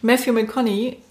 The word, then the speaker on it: Matthew McConaughey: Und zwar ist mir jetzt ähm, besonders hängen geblieben Matthew McConaughey.